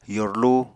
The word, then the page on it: yorlu: Apatani